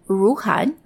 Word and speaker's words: Ruhnn: It's called Ruhun, that's spelled R-U-H-N-N, and it's just filed for IPO a few weeks ago here in the US on the NASDAQ.